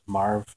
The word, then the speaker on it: Marv: And I should say that my name is Marv. Now, it's spelled K-Y-L-E, but it's pronounced Marv.